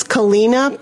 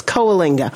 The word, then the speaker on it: Coalinga: I think they pronounce Kalina, but they're wrong. It's Coalinga.